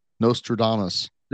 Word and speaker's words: Nostradamus: Nostradamus.